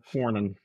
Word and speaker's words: Cornyn: Cornyn.